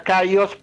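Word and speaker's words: K4OS: Es caos con K y la A es un 4 en cuanto al nombre del grupo. Y se las pronuncia caos porque sería, digamos, caos en inglés.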